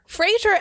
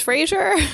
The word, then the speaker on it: Fraser: So my maiden name is Fraser. And if you couldn't put the pieces together. – No, but, yeah. Like we say Fraser, but if it's F-R-A-S-E-R, it's really Fraser. Everybody's saying it wrong.